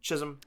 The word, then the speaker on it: Chisholm: Jazz Chisholm. Did I pronounce that right? Chisholm? – Jazz Chisholm. – Chisholm, thank you.